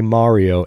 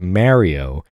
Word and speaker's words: Mario: I was very careful just then to say Mario and not Mario, as I know it to be pronounced because I'm from New York.